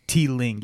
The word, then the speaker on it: Teeling: I think I've heard of, you said it was Teeling, right? Am I pronouncing that correctly? – Teeling.